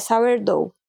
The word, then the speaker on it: sourdough: Tem uma ali que eu não entendi como é que fala, sordô? É, essa é uma expressão inglesa daí, é sourdough. Sourdough, é, tá.